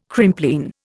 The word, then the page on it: Crimplene: Indian